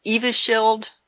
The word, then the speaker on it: Evusheld: The FDA approved Evans Held or Evanscheld, I guess that's how you pronounce it, Evusheld.